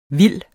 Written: vildt

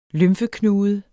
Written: lymfeknude

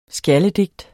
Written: SKJALDEDIGT

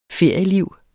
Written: ferieliv

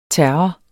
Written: terror

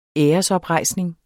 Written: æresoprejsning

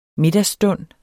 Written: middagsstund

